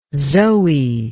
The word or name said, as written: zoe